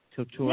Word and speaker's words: couture: Couture? – Yeah, mm-hmm.